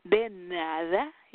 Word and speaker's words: de nada: It's like doom dada. De nada. You have to say it like de nada, like my pleasure. De nada.